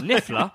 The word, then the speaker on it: Niffler: I was like, Niffler? That rhymes with Stifler.